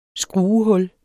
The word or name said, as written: skruehul